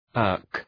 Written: irk

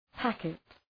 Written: packet

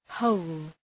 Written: hole

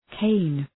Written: cane

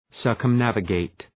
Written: circumnavigate

